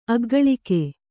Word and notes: ಅಗ್ಗಳಿಕೆ: Kannada

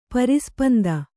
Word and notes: ಪರಿಸ್ಪಂದ: pari spanda

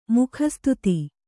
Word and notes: ಮುಖಸ್ತುತಿ: kannada

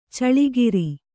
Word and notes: ಛಲಗಾರ: Kannada

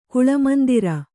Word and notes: ಕುಳಮಂದಿರ: kannada

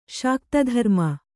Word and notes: ಶಾಕ್ತಧರ್ಮ: Kannada

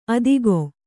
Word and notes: ಅತ್ತಿಗೆ: Kannada

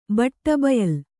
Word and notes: ಬಟ್ಟಬಯಲ್: baṭṭa bayal

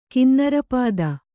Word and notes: ಕಿನ್ನರಪತಿ: kinnarapati

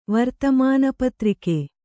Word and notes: ವರ್ತಮಾನಪತ್ರಿಕೆ: vartamāna patrike

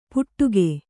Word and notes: ಪುಟ್ಟುಗೆ: kannada

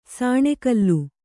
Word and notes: ಸಾಣೆಕಲ್ಲು: kannada

